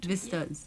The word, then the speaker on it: Vistas: So from the Vista in Education, mm-hmm. Did I say it right? – Vistas, yeah. Yeah. And, the S's.